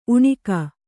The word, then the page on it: ಉಣಿಕ: uṇika